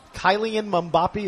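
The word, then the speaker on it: Kylian Mbappé: Kylian Mbappe, is that his name? – Yeah, that's it, right. That was my way of pronouncing it.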